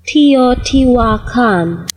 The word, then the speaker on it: Teotihuacan: Teotihuacan. – Damn, what a dope language. What a dope language. Teotihuacan. People just like, when they believed in magic, they had cooler ways of describing things. Teotihuacan.